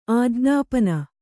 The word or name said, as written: ಆಜ್ಞಾಪನೆ